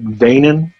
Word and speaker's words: VENIEN: Like, it said VENIEN, like a vein and N, VENIEN.